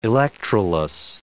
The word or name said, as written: electroless